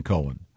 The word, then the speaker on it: Cohen: No, not when it's spelled C-O-H-E-N. – Yes. It's definitely Cohen.